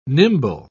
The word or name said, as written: nimble